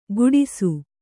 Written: ಕುಡಿಸು

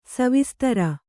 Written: ಸವಿಸ್ತಾರ

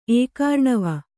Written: ಏಕಾರ್ಣವ

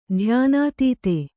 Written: ಧ್ಯಾನಾತೀತ

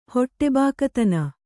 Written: ಹೊಟ್ಟೆಬಾಕತನ

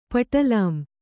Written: ಪಟಲಾಂ

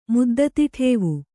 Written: ಮುದ್ದತಿಠೇವು

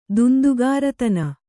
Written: ದುಂದುಗಾರತನ

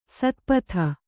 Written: ಸ್ಥಪತಿ

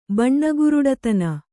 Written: ಬಣ್ಣಗುರುಡತನ

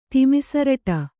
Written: ತಿಮಿಸರಟ

